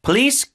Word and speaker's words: police: Police crackdown. Crackdown의 강세. Police는 앞에 푸로 발음하세요. 포가 아니라 police crackdown.